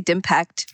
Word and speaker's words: DIMPACT: How do you pronounce this? – What's it? I think we just say DIMPACT, but DIMPACT. Yeah, it's digital impact. So it can be either, but yeah, DIMPACT.